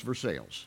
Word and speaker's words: Versailles: We're playing Versailles. Versailles is a really, always really good. Now, you may know it's Versailles, Ohio. You may spell the same way as Versailles, but in Ohio, it's Versailles, okay?